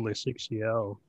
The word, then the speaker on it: Le Chic Chiot: Ooh, Le Chic Chio. Tell me about it. – It is spelled L-E-C-H-I-C-C-H-I-O-T, and it is a small business for animal accessories.